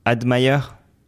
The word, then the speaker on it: Admire: On va voir avec lui comment on prononce. – Salut Rémi, salut John. – Tu préfères quoi? Admire? – Admire en français.